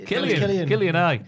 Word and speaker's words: Cillian: Killian Murphy. – Is it? – You're being Cillian Murphy. – I was trying so hard. – Why? Why? – It's really silly. And why did they? – Killian.